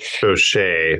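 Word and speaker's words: Bosché: 1980 Freemark Abbey Bosch Cabernet Sauvignon for $130. – Boucher. It's pronounced Boucher. – Boucher, whatever. Boucher, sorry.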